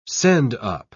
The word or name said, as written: send-up